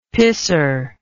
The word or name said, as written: pisser